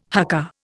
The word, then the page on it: haka: Indian